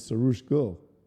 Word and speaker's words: Saroosh Gull: And today's special guest is Sarush Gul.